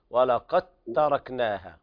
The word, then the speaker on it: ولقد تركناها: الدال تضغم في التاء لا تنطق الدال. ولقد قاف تاء مباشرة. ولقد تركناها.